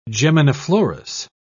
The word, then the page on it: geminiflorous: British English